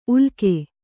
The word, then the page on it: ಉಲ್ಕೆ: ulke